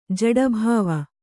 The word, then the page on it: ಜಡೀಭಾವ: jaḍībhāva